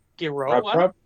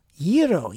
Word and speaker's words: gyro: They're like, it's a gyro, gyro, gyro, you know, because that's the Greek pronunciation.